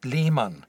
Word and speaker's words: Lehmann: Mein Nachname ist Lehmann. Das schreibt man L-E-H-M-A-N-N.